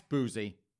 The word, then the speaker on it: Boosie: You spell that, just in case you're wondering, B-O-O-S-I-E, that's Boozy, and Badass, that's B-A-D-A-Z-Z.